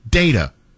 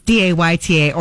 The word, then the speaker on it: data: Text or call with your data. – And if you say it like Jeff, it's DAYTA, or you can read it like it actually is, data.